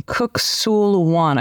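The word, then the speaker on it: Kuk Sool Won: and a second degree black belt in Kuksulwan. I hope I'm pronouncing that correctly. – Yes.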